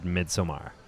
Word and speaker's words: Midsommar: We'll see what the consensus is when, because that is how the writer and director of this pronounced it. – Yeah, Ari Aster said Midsommar.